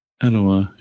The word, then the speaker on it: Etowah: In Athens and Etowah, Tennessee. – Etowah, yeah.